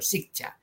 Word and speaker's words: Siccha: Discúlpeme, es que como a mí me dicen siempre casi mal, casi siempre mal mi apellido, por eso quería decir bien, bien el suyo, Sikcha.